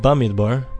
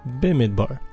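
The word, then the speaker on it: Bemidbar: This week's Parsha is Parsha's Bimidbar, commonly called Bamidbar, but actually it's pronounced Bimidbar.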